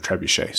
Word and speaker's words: trebuchet: where you accidentally mispronounced trebuchet's trebucket. And now people think that we actually say trebucket instead of trebuchet.